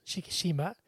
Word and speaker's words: Shikishima: And I think someone pointed out that at the end of the movie, after Shikishimi, is that how you say his name? – Shikishima.